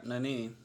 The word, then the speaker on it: Nannini: How do you how do you pronounce that? – Yep, Nannini. – No, you nailed it, man.